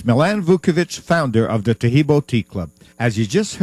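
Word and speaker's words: Taheebo: Taheb is spelled T-A-H-E-E-B, like boy, O, then the word tea, and then the word club.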